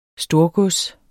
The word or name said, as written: storgods